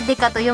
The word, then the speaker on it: 刑事: これ刑事と書いてデカと読むんですけど、大事なポイントです。